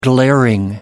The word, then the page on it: glaring: American English